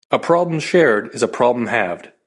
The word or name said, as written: halved